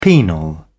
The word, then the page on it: penal: British English